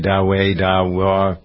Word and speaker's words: Dahua: Dahua. Is that how you pronounce it, Victoria? Dahua.